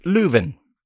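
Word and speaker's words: Löwen: That last name is spelled L-O, don't forget the umlaut, W-E-N, pronounced Leuven.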